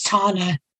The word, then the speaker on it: Tana: one of the easiest names on there was Tana, T-A-N-A, which is my name.